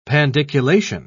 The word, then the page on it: pandiculation: British English